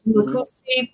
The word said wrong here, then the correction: Nkrumah: I'm not saying his name right. – Nkrumah.